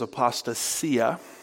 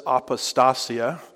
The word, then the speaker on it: apostasia: Now, the Greek noun for apostasy is apostasia, or some pronounce it apostasia. Depends on which syllable gets the emphasis, as I like to say.